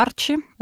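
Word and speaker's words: ARCI: Эта система называется клуба Арчи. Э, вот э, Чи произносится на концах, тебе пишется Си.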